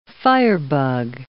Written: firebug

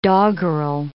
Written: doggerel

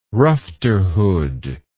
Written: rufterhood

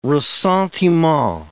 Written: ressentiment

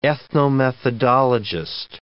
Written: ethnomethodologist

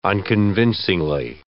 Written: unconvincingly

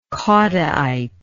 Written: caudaite

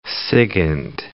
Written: SIGINT

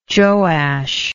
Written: joash

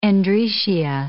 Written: androecia

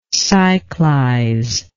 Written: cyclize

